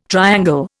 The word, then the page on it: triangle: Indian